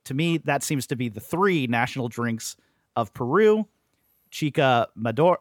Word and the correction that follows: chicha morada: I'm sorry. I mispronounced all the way through. Chicha morada.